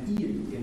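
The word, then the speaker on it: îles: It's il, and it's spelled I-L-L-E-S. So, an il in French, an il is an island. I, accent circonflexe, L-E, right? And il is he, I-L. L-E-L-L-E is her. So it's this incredible... what she wrote is I-L-L-E-S, and what it would mean is islands, but it also contains both masculine and feminine in it, and it says islands.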